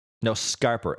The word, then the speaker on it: scarper: Scamper? – Scarper. S-C-A-R-P-E-R. – Okay.